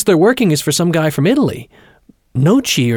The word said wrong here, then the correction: Notte: or something." – "Notte," Etienne corrected, pronouncing the name Notte.